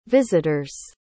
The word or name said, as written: visitors